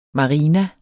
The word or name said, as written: marina